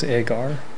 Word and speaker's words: Agar: Or in Galatians it says Agar, with an A, silent H, apparently, in Greek.